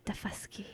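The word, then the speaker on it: Daufuskie: Yeah, Defusky.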